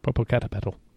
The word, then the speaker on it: Popocatepetl: It's Popocatepetl. – Popocatepetl.